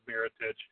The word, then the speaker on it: Mirotic: Mirotic.